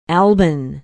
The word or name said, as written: alban